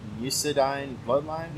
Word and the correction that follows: Muscadine Bloodline: Muscadine Bloodline.